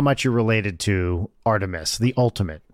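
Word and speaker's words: Art3mis: The blog of a female Gunter named Artthremis, parentheses, pronounced Artemis.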